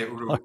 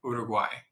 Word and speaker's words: Uruguay: People say it a lot different. Here we say Uruguay. – Right. – Uruguay.